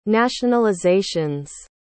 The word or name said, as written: nationalizations